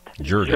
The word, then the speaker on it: Jordan: That's spelled Jordan, mm-hmm. – but pronounced Jordan.